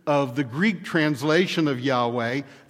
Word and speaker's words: Yahweh: God's proper name, then, is pronounced Yahweh. Somehow, I got in the habit of pronouncing it the way that the Germans do, with the W taking the sound of our V, so I often say Yahweh.